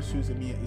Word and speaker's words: Suzumiya: Her name is spelled H-A-R-U-H-I, and it's Suzumiya. So it's S-U-Z-U-M-I-Y-A. Hiro Suzumiya.